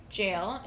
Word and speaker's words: Gaol: It was the Ottawa Jail, or also known as the Carlton County Jail, is how Kat says to pronounce it. It's spelled G-A-O-L. – Yeah, I think it's Old English. – So it could be jail.